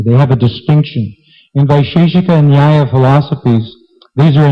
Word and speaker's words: Vaisheshika: Furthermore, we're not done yet, there are other theoreticians, namely the proponents of Nyaya and Vaisheshika. Now here we can look at the Sanskrit here. Vaisheshika. Vaisheshika. Ka. There's no she in this word. There's no long I.